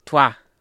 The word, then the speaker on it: Troyes: Troyes.